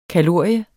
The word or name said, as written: kalorie